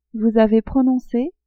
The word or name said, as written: vous avez prononce